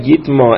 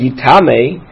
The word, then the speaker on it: Yitma: Yitma, but the fact that it's spelled, that it's read Yitma and it's not read Yitame, so it tells us at the end of the day it limits it.